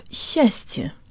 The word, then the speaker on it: счастье: Let's work on the first syllable of счастье. Together, S and Ч sound like sh, with the tongue high and forward, and to Western ears it almost sounds like there's a Y sound built in.